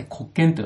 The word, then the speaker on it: 国権: ちょっと難しい言葉なんですが、国という字に権利という字を書いて国権と読みます。